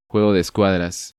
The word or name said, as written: Juego de escuadras